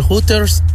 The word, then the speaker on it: Hooters: Así no se dice, don Poncho. – Entonces, ¿cómo se dice? Dile a don Poncho. – Se dice Hooters. – Hoy no más. ¿No? – Bueno, pues sí, ¿dónde? Porque la H es muda, Hooters.